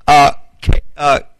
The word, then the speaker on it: Carmi: His son was Carmi. It's pronounced K-A-R-M-I.